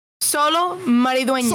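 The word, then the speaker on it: Xolo Maridueña: Maridueña. – How do you say the name? – Yeah. Solo Maridueña.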